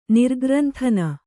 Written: ನಿರ್ಗ್ರಂಥನ

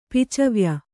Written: ಪಿಚವ್ಯ

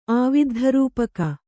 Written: ಆವಿದ್ಧರೂಪಕ

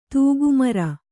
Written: ತಿಗಮರಿ

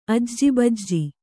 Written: ಆಜೂಬಾಜು

